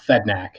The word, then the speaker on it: Fedynak: It's originally a Western Ukrainian name, and it's transliterated and then anglicized, both from changing the alphabet and then changing the pronunciation to what it is now, Fedynak here.